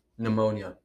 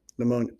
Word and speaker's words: pneumonia: Pneumonia. – Pneumonia. Pneumonia. – It's here, but don't you say it.